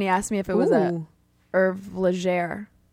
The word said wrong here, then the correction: Légère: Is that how you say it? – Légère.